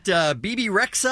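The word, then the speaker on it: Bebe Rexha: Talking about BB Rexha, I believe is how you say her name.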